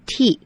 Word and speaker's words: won't: 不过呢，我们常常把 will not 缩写，就变成 won't。那这时候要怎么念呢？No, I won't. – 对呀、啊，那只念 won't. Will not 缩写念 won't.